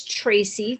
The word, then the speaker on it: Tracy: My name is Tracy, T-R-A-C-Y, and I would spell it T-R-A-Y-C-E-E.